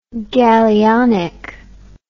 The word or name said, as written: gallionic